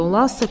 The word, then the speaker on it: Лассо: Ну, ударение ставят по-разному, кто Ласса, кто Лассо.